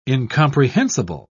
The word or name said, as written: incomprehensible